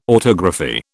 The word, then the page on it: autography: British English